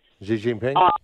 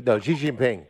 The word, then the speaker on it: Xi Jinping: Xi Jinping? Xi Jinping.